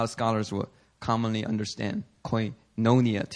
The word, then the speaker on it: koinonia: Koinonia. – Actually, it should be koinonia. The accent is on the O, the second O.